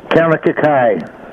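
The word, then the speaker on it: Kaunakakai: It's Kanakakai.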